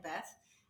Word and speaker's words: Bath: That's how they say it in Bath. In Bath, that's how I always said it.